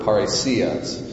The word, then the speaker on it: parrhesia: And that phrase, boldness of speech, is a very interesting Greek word, parrhesia. That's how you say it in the Greek.